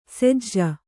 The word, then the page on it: ಸೆಜ್ಜ: sejja